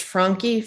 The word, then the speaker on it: Franke: I can't pronounce it correctly. It's Frankie, Frankie, F R A N K E.